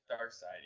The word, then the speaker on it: Darkseid: So there was a recent Justice League movie where Barry had to do it once again because Darkseid takes, you know, Thanos. – It's spelled to look like seed, but it's really side. – Dark side, yeah.